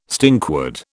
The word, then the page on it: stinkwood: British English